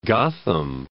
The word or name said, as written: Gotham